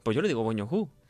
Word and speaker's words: Bong Joon-ho: Mejor director, Bong Joon-ho. – ¿Eh? – ¿Lo he dicho bien? – Sí, Bong Joon-ho.